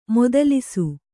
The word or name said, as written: ಮದಾಲಸ